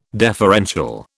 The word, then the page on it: deferential: British English